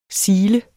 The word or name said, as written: SIL